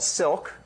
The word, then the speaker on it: SILC: Okay, so we've developed a dynamic multi-threaded language called SILC, spelled with a C because it's based on the language C. And it's not an acronym. It's because silk is like nice threads.